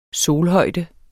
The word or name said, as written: solhøjde